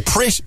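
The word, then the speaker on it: Pritt: sorry, Prit. It was Prit, you said, wasn't it? – Prit. Sorry, I'm Prit. With two T's. Okay. Very Prit. It's the emphasis on the T's.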